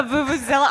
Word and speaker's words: vuvuzela: That's a vuvuzela. – A vuvuzela. Are you sure that's how you say it? – I nailed it.